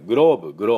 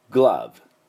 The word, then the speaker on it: glove: で、なんとですね、我々が普段使って野球とかで言ってるグローブ、グローブ、あれはですね、英語でなんて発音するかというと、グローブ、グラブ、アなんです。